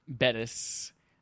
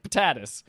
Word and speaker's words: Bettis: We're going to be having Trevor Bettis. I just remember the first time I tried to pronounce his last name. It was like Patatus.